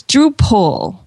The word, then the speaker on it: Drupal: It's Drupal, like P-U-L-L. – Ah. – Oh. – Like, I mean, it's spelled P-A-L, but it's Drupal is how you pronounce it.